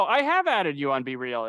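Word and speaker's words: BeReal: Yeah, I am now on Be Real. – Be Real. – I like to pronounce it like it rhymes with cereal.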